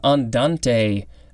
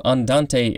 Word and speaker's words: andante: Orthorhombic is spelled O-R-T-H-O-R-H-O-M-B-I-C. Next we have andante. We have two forms, and they both will be the last for this episode. A-N-D-A-N-T-E. It could also be pronounced andante.